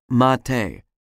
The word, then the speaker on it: mate: For example, there is one kind of tea brewed in South America called mate, spelled M-A-T-E, but pronounced mate.